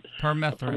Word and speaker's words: Permethrin: Permethrin.